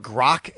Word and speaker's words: Grok: Joe, do you know what Grok is? Not Gronk. Grok? Grok? Grok? How do you say it? – G-R-O-K?